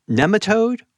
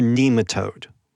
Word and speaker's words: nematode: Nematode or nematode? – Yeah, is there some people that really feel strongly about how to pronounce that term? They really think it should be pronounced nematode. And so when I'm hanging out with them,